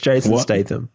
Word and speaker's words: Jason Statham: It's Jason Statham.